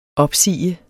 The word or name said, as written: opsagdes